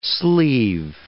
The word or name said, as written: sleave